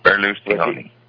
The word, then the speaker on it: Berlusconi: Berlusconi. – Berlusconi. – Yes, Berlusconi. – Oh, Berlusconi. – Berlusconi. – Oh, that's how they say it.